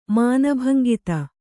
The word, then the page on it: ಮಾನಭಂಗಿತ: māna bhangita